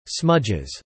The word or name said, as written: smudges